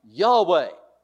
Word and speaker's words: Yahweh: Yahweh. See, that's the way we have come to pronounce YHWH.